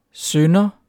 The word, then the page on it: synder: dansk